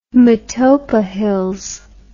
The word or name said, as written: Matopo Hills